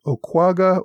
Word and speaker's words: Onaquaga: I pronounced it Onakwaga. I know that many popular pronunciations call the village Okwaga or Ogwaga, making it a three-syllable word rather than the four syllables I used.